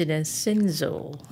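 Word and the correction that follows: Sehnsucht: Sinzucht. It's the spelling is S-E-H. – Yeah. – And S-U-C-H-T, right? – Yeah.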